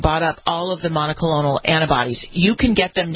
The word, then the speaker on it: monoclonal: Well, the autoclonal, how do we say it? How do you say cucumber? – Monoclonal. – Monoclonal, right. Thank you.